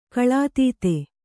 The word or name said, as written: ಕಳಾತೀತ